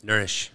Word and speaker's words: Nourish: And sitting right next to me is my good friend with Nourish. – That's me. Did I say that properly? Nourish. – Nourish. See, I'm such a foreigner. – So it's like the same exact word nourish, just spelled different.